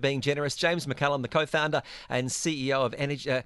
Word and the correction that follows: Xergy: So you pronounce X strong. Xergy. – Xergy.